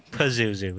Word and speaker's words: Pazuzu: Wait, so how come before when Violator said Kazuzu? – Pazuzu with a P.